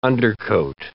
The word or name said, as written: undercoat